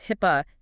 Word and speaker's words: HIPAA: HIPUH, is that pronounced HIP? – Yes, HIPAA.